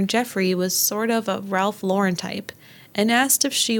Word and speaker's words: Ralph Lauren: And let's say it correctly, Ralph Lauren.